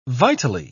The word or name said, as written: vitally